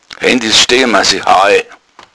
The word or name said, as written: heil